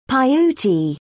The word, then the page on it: peyote: British English